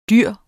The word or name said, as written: dyr